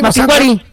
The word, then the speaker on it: Mapinguari: No, es que no tiene acento en la I. – No tiene acento en la I. – Mapinguari.